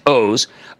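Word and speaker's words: Oz: My second favorite, I know it's O-Z, but it's pronounced O's.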